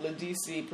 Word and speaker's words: Ledisi: So anyway, so people have taken issue with Beyonce singing the song that Ledisi performed. – Ledisi. – Is that what it is? – You said it right the first time. Ledisi.